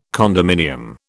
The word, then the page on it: condominium: British English